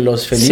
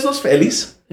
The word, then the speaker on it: Los Feliz: Yo creo que a pesar de que se llama Los Feliz, y aquí en Los Ángeles hay un Los Feliz, sí, sí. – Que se pronuncia... – Los Feliz, que está muy mal dicho. – Pero en español es Los Feliz.